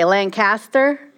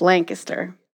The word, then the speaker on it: Lancaster: Because at first, you used to say Lancaster. But then people told me it's Lancaster.